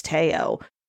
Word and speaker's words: Teo: We thought pronouncing T-E-O, we thought we were supposed to pronounce it like Saul, like Park Seo Joon. – Park Seo Joon, yeah. – And Grace was like, no, and she said actually for his name it's Tae-o. So just so you know, that's why we're pronouncing it.